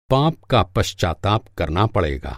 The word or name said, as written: पश्चाताप